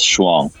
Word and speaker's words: Shuang: If you say Shuanghai from now on, you can't ever mispronounce it.